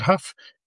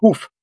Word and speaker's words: Huff: Well, for English-speaking people, they pronounce it Huff. We came out of, we left Poland in 1846. – Oh. – My ancestors spoke German, so they... – Ah, right. – So in Europe, it's pronounced hoof. Like a horse's hoof. – As an H-O-O-F.